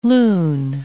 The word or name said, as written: lune